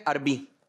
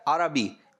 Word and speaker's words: عربی: عربی تو عربی تو صحیح ادائیگی نہیں ہے صحیح نہیں ہے صحیح تلفظ کیا ہے عربی عین پر بھی زبر